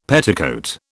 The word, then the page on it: petticoat: British English